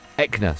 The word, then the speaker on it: Eckner: German broadcaster Konstantin Elkner, is that how you say his name? – Eckner. – So I've got the first bit right? – Yeah.